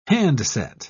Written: handset

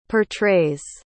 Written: portrays

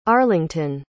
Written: arlington

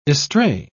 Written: estray

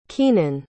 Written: keenen

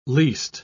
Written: least

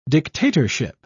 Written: dictatorship

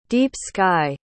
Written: deep-sky

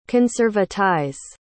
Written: conservatize

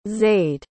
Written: zayd

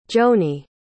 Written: johny

